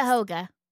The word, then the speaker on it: Cuyahoga: How's it pronounced? – Cuyahoga.